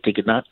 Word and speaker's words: DGNAK: It's pronounced as Diganak.